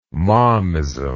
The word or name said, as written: momism